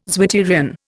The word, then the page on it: zwitterion: Indian